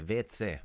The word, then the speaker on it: WC: So vetse is basically the letters WC pronounced the Croatian way, and WC is water closet.